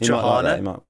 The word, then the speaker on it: Trahana: We'll go with Trahana. Rhymes with banana.